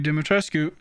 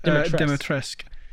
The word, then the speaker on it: Dimitrescu: Lady Dimitrescu, sorry, actually pronounced Dimitrescu.